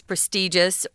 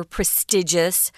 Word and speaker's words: prestigious: Another word for that is prestigious or prestigious. Uh, there are two pronunciations for that word.